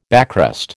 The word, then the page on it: backrest: American English